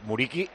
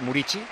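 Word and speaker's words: Muriqi: Muriqui, dijimos otro día, se dice Murici. – ¿Murici? – Se sí, dice yo, Murici.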